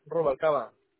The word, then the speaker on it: Rubalcaba: Rubalcaba.